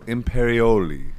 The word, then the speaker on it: Imperioli: Michael Imperioli. Is that how you say it? – That's exactly right. – Imperioli.